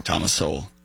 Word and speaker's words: Thomas Sowell: Tom Sowell, how did you say that name? – I'm just gonna let you do it. – Okay. People with their time on their hands will inevitably waste the time of people who have work to do. – Thomas Sowell. – Sowell. Sowell. I see well. Sowell. – Sowell. – Sowell. – Thomas. Wait, it's actually on. Thomas. Stop. It's Thomas. Sowell.